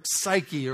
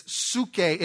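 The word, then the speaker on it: psuche (ψυχή): The Greek word is psuche, or psyche, or whatever, however you pronounce it. P-S-Y-C-H-E. Sound familiar? Yeah. But it's psuche, and it means breath of life, or that in which there is life.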